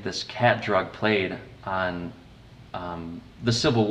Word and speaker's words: qat: And there are so many times, I marked every single time, I think, in the book, when you mentioned a drug called Cat, is that how you pronounce it? Mm-hmm. Q-A-T? – Yep.